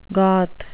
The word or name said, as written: goth